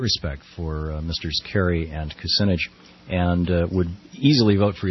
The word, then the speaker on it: Kucinich: Diana's wondering, in that order, is that, you know, Kerry, Dean, Kucinich, is that the order in which, and am I mispronouncing Kucinich? – That's how I understand to pronounce it.